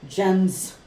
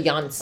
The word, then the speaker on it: Jans: Jens. It's actually Jans. – Oh, is it Jans? – If it's Dutch, it's Jans. – Jans. – The J is pronounced with a Y.